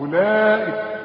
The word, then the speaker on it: أولئك: كل ما تلاقي حرف عليه دوران ما تنطقوش، يلغى في النطق، يكتب ولا ينطق، وبيسمها حروف علة. كم حرف من حروف العلة دول في القرآن؟ ثلاثة، الواو والياء والألف. فإذا لقيت حرف عليه خمسة الغيه من النطق. اشبك الهمزة مع اللام على طول، أولئك، ومدني اللام به أربع حركات.